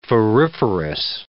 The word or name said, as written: ferriferous